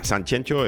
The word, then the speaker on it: Sanxenxo: en gallego es con doble X, algunos se empeñan en escribirlo con G y con J y pronunciarlo como les dé la gana. Sanxenxo es como se debe decir,